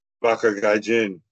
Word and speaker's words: Baka Gaijin: And then, you know, that pretty well led to the reunion of sorts of, I can never say this, the Baka Ganja, or Baka Ganja, how do you say it? – Baka Gaijin. – Baka Gaijin. – Yep.